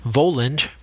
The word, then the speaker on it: Voland: Voland, pronounced as if spelled Wayland,